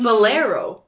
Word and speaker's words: Bolero: I kept wanting to say Borello, and I'm like, I know that's not it. – Yeah. Borello's the little sweater, yes. – Right? – Yeah. Okay. – Bolero. – Yes. Sorry, I said it wrong.